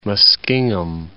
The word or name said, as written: Muskingum